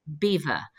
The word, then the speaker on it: Belvoir: And as we know, the English have never been able to pronounce the French. And so over the generations, it became Beaver,